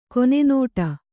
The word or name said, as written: ಕನ್ನುಡಿ